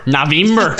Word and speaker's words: November: Is it pronounced Navember? – Navember. Navember Sanguinar.